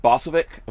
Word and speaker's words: Bosovik: Is it Bosovik? Am I saying that right? Yes, sir. Right on point. – Pavel Bosovik of 27 North.